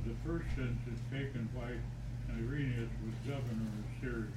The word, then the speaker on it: Quirinius: Quirinius is how you pronounce that name, just so you know.